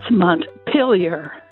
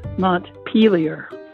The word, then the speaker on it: Montpelier: I currently live in Burlington, and I think the capital of Vermont should be pronounced Montpelier. – So that's Montpelier, not Montpelier.